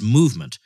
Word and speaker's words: MVMT: MVMT, pronounced movement, has hundreds of premium, affordable sunglass styles to choose from.